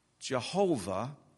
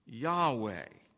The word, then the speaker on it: Yahweh: Transliterated, it would be YHWH, that many people pronounce Jehovah, and some other people pronounce Yahweh. That's probably the... it's the way at least the modern Hebrew scholars prefer to pronounce it.